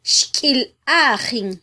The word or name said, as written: xkyilajin